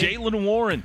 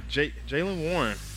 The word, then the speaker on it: Jaylen Warren: Jaylen Warren. – Jaylen Warren.